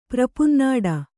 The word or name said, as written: ಪ್ರಪುನ್ನಾಡ